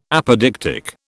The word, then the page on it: apodictic: British English